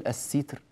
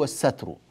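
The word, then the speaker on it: السَّتر: نعم، فالإخفاء معناه في اللغة الستر، لا نقول الستر، الستر هو المخفي، الشيء الذي يخفي هو الستر، لكن الإخفاء المصدر فعل الإخفاء نفسه نقول هو الستر بفتح السين،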